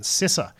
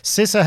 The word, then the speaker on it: CISA: I got to remember that's the correct pronunciation. CISA. CISA